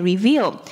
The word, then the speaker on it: reveal: 那要注意的是呢， reveal 这个字它后面是 eal 发长音 e，所以它是念 reveal。